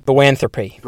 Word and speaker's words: boanthropy: Bonotherapy. It's probably not how you say it, but I'm going to call it that. – Let's have a look. – Yeah. Can you get him to say it properly? – Bonotherapy, yeah. – Thropy. – Boanthropy. – Boanthropy. – Boanthropy. – You still don't sound like it, what it should sound like.